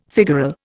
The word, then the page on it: figural: Indian